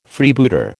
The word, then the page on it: Freebooter: American English